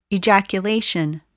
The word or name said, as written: ejaculation